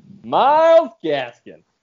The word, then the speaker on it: Gaskin: Miles Gaskin. I feel like you gotta say it like how Joe Exotic said Carol Baskin.